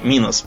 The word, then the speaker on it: Минос: Э, Минос поскупился. минус правильно. Минус, Минус. Да, да, да. да. Только хотел тебя поправить, пошел в Википедию смотреть, как, как ударение да, ставится.